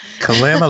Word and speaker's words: Kalama: Kalama, like calamity. – Like calamity, exactly.